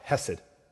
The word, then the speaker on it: hesed: His steadfast love, hesed, is the Hebrew word. If you want to say it right, you got to get the phlegm going.